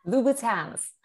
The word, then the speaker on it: Louboutin: Is it Louboutin? – Louboutins.